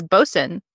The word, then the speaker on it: Boatswain: Okay, it is Boxen. – Boxen. Okay. – This word is spelled like boatswain, so don't judge us.